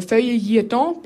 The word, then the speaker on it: Feuilleton: Weil die sollte ja im Feuilleton platziert werden. So spricht man es wirklich aus, wenn man sich Zeit lässt.